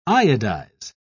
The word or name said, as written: iodize